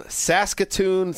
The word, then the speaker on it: Saskatoon: Kevin from Saskatoon, Saskatchewan. Is that being pronounced correctly? I'm a little off on my Canada stuff. – Perfect.